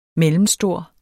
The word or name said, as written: mellemstor